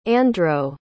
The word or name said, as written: Androw